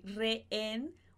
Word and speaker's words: rehén: Então, esse rehén, o H, a gente não vai pronunciar, mas os dois S a gente vai pronunciar.